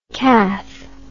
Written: cath

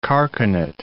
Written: carcanet

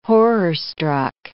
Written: horror-struck

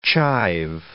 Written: chive

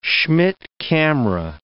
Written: Schmidt camera

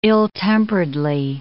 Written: ill-temperedly

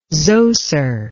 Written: zoser

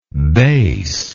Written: bassy

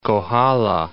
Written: Kohala Mountains

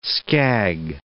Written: skag

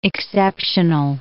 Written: exceptional

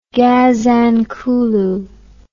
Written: gazankulu

